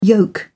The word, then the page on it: yolk: English; British English